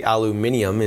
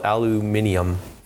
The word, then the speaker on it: aluminium: He told me the reason they say aluminium is because it is actually spelt differently.